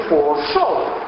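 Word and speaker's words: Fuo Shou (佛手): Se dice Fuo Shou, Fuo, no puse mano, así sí, Shou. Ustedes conocen el Shou, ¿no es cierto? Del Shou Yi, ¿se acuerdan que vemos el lugar? De Shou de mano y de máquina, Shou Yi. Entonces, Shou, tercer tono, mano, se pronuncia igual o parecido a Shou, que es la realidad.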